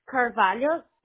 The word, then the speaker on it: Carvalho: So, your first name, you spell it N-E-N-C-I-L-L-Y, correct? – N-E-N, yes, correct. – Okay. And what's your last name, Nancy? – Carvalho, C-A-R-V-A-L-H-O. – Okay. So C as in cat, A-R, V as in Victor, A-L-A-H as in Henry, O as in Oscar? – Yes.